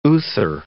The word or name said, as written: Uther